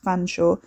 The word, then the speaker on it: Featherstonehaugh: The senior Mr. Cholmondeley, pronounced Chumley, of course, in the way that Featherstonehaugh was pronounced Fanshawe, had never failed in this respect, although as far as Wes could tell he'd failed in almost every other.